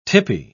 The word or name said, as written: tippy